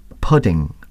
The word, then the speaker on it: pudding: Pudding, but the correct English pronunciation is pudding. That's it, pudding. And it's very difficult for me to say that because I'm used to saying pudding.